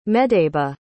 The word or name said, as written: medeba